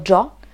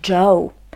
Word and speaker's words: Joe: Zacznę jednak od wymowy, bo to imię można wymawiać na dwa sposoby: albo Joe, albo Joe.